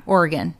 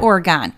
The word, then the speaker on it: Oregon: So yeah, Oregon, not Oregon and not origami, Oregon, Beaverton.